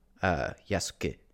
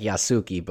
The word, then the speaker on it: Yasuke: Yasuke, and he's the voice of the main character. I'm trying to say it like they say it. It looks like Yasuki, but that's not how they say it.